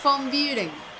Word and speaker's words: von Büren: El, apellido es alemán, entonces yo no sé hablar alemán, así que tal vez yo tampoco lo pronuncie bien. Es von Buren.